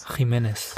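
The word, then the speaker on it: Jiménez: wie spricht man es denn aus in Spanisch? – Jimenez.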